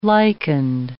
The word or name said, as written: lichened